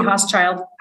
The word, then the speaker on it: Hauschild: Staci Hauschild.